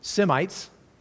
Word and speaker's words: Semites: So Shem, the father of the Shemites, we pronounce it Semites, Semitic, right, which is going to include the Hebrew people in their line.